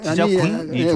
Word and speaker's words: 지자군: 아니, 지자군이 좋을까요? – 군으로 발음하는 것이 한자 표기이기 때문에 지자군 하는 것이 맞는 발음이에요.